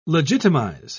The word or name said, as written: legitimize